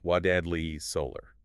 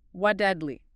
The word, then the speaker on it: Waddadli: It's spelled W-A-D-D-A-D-L-I, but it's pronounced Wadadley Solar. – Wadadley. Got it.